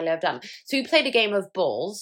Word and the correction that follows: bowls: Is that how you say it? – Bowls. – Balls.